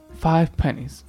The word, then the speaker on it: Five Pennies: Five Pennies.